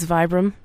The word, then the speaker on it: Vibram: And these are made by Vibram or Vibram, however it's pronounced. – I think it's Vibram. Yeah. – Yeah. – Like five fingers. – Yep. – Is a long I or whatever.